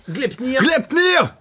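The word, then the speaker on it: Gleipnir: Alors, comment prononcer ce titre? – Glepnir Hartung.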